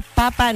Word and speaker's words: papa: ¿Tienen ustedes alguna idea de alguna preparación que se puede hacer con la papa justo en el día de la papa? No es el día del papá, no. Día de la papa. Acento en la primera A. No en la última, no, no, no.